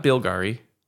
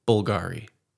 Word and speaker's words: Bulgari: I think it's Bulgari. – Okay. – Which is not, more or less how Bulgari. You said it. Bulgari. – Okay, Bulgari. Not Bilgari. Bulgari.